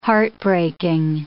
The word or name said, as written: heartbreaking